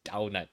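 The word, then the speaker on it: Damhnait: Now, Dimphna, or rather Daunad, the actual Irish pronunciation of her name is Daunad. Now, I know you're probably thinking, that sounds like donut.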